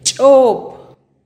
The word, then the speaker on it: chop: Piña en pocomam se dice chop, chop. Repite conmigo, chop.